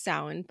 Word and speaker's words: Samhain: And I probably said that super wrong. I'm sorry. Don't have any Irish ancestry. – It's a hard word, you guys. – But it's pronounced Samhain.